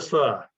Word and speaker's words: pho: What is what the fur? Vietnamese soup, pho, fur. Ah. It's actually, I have it on very good authority that it's said fur, even though it's spelled pho.